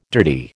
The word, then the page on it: dirty: American English